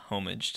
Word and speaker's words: homage: A lot of video games will do like Easter eggs and stuff to like pay a homage to that code. – Homage, the H is silent.